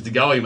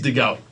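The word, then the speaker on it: De Goey: He goes, "Oh, I think his name's Dehoey, mate." I go, "All right, well, I've only ever known it as Dehoey." He's like, "All right, we'll pronounce it the Aussie way." So it is Dehoey.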